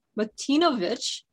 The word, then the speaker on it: Martinovich: I'm here with special guest, Jeff Martinovich.